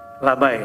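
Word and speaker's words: lebai: Labay ya. Apa? Labay. Labay itu apa? Ustaz. Ketika engku, tapi tulisannya lebay. Labay ya bacanya.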